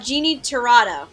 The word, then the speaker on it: Jeannie Tirado: And for a newer voice, and I will probably butcher the last name, Jeannie Tirado. – You actually got it right.